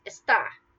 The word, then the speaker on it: está: For example, esta versus está.